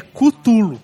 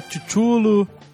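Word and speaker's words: Cthulhu: Muitas pessoas mandaram e-mail dizendo que a pronúncia correta, ou a mais correta, ah. é Cutulo. Cutulo, não é Chuchulo.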